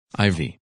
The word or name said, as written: Ivy